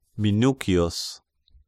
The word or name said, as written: Minucius